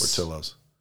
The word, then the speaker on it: Portillo's: Portillo's.